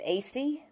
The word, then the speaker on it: Aiesi: Sure, it's Detective Acey. – Acey. – It's spelled A-I-E-S-I, and I'm with the Myrtle Beach Police Department here in South Carolina.